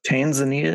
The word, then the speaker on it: Tanzania: Tasmania and how do you say that? – Tanzania and Mozambique.